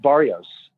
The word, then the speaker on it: Barrios: I am at Jarrett Barrios on Twitter and on Instagram.